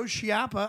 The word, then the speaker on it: Schiappa: And then we have Joe Schiappa.